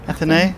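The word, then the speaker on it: Athénée: The suite in is it called the Plaza Athene? Is that how you pronounce it? – Athenae.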